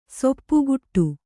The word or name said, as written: ಸೊಪ್ಪುಗುಟ್ಟು